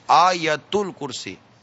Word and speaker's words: آیت الکرسی: حمزہ پر مدہ ہے, یا پر زبر ہے, اور تا پر پیش ہے. آیت الکرسی, پھر کہہ لیں آیت الکرسی.